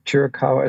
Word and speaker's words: Chiricahua: I think it's to the Chiricahua site. Is that how you say it? To check out the stars? – Yeah, the Chiricahua Astronomy Complex.